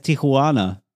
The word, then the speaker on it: Tijuana: Da sage ich, das heißt Tijuana.